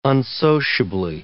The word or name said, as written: unsociably